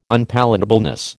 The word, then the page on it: unpalatableness: American English